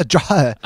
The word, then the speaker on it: Dreja: It's a Dreja.